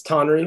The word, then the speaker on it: Tonry: Yeah, that's not bad. That's not bad. It's Tonry, but Tonry.